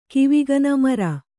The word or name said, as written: ಕಿವಿಗನ ಮರ